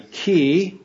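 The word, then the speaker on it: chi (χ): Greek letter X is actually chi. That's how they say it.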